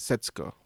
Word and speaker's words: Setsuko: It's more like the ooh is kind of swallowed. It's kind of Setsuko.